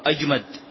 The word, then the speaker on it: أجمد: أحمد وأجمد، الأولى مهملة بحاء مهملة، والثانية بجيم منقوطة، معجمة منقوطة، أجمد.